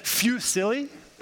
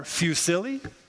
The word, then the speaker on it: fusilli: So which syllable do you stress now? Is it fusilli or fusilli or fusilli? Some of you probably know, right? Well, in this case, it would actually be the second one.